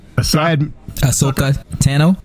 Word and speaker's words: Ahsoka Tano: So TV, first off, Rosario Dawson, which is some awesome news for Mandalorian season 2, is, I'm going to butcher this name because I never watched the Ahsoka Tano. – Ahsoka, yes. Tano.